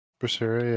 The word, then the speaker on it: Berseria: You'll be leading us in this next topic because you're currently playing, hopefully I say it correctly, Tales of Berseria? – Berseria, yeah.